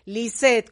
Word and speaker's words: Lisette: Lisette con doble S y doble T, así como se escribe mi nombre.